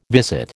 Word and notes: visit: American English

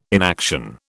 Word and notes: inaction: British English

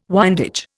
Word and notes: windage: Indian

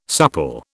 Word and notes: supple: British English

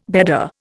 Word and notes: bedder: Indian

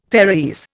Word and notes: Faeroese: Indian

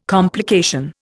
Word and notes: complication: Indian

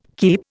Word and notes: keep: Indian

